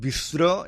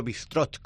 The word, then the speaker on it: bistró: Y se conoce eso, es bistró, nosotros le ponemos el acento bistró, aunque en francés, bueno, se pronuncia de esta manera, incluso bistrot con T final,